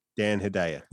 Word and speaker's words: Hedaya: You got Mel Gibson in the lead, Rene Russo, Gary Sinise, Liev Schreiber, Delroy Lindo, Lili Taylor, Donnie Wahlberg, um, Dan Hayeda or Hedja. I don't know how to pronounce his name. Nor do I know how to pronounce Paul Paul Guilfoyle's name. Hedaya. Yeah. Dan Hedaya. Dan Hedaya.